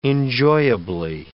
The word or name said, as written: enjoyably